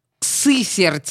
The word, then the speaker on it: Сысерть: Вообще, еще то, что я хотела сказать об этой девочке, что она из городка, я записала себе, городок называется Сысерть. Вот, вот так это произносится, я не буду пытаться еще раз это сделать.